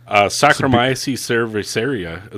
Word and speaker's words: Saccharomyces cerevisiae: Sacrimonies. What is that? – Saccharomyces cerevisiae is what it says.